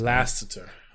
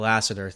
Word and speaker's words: Lasseter: So the quote of this week is from Pixar's Principles by their own creative officer chief, John Lasseter. Hopefully I pronounced this. – Lasseter. – Name. Lasseter. Thank you.